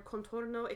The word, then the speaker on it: contorno: It is pronounced Contorno. – Thank you. – As in container.